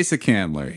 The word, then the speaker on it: Asa Candler: So enter, I think his name is Asa Candler. Is that how you pronounce it? – Sounds right. – Yeah. April 14th, 1888, Asa Candler.